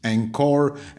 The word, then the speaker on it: Anchor: Euh, vous allez, euh, le titre c'est Réussir son anglais sur euh, le site Encore. Encore s'écrit A-N-C-H-O-R.